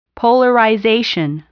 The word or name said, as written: polarization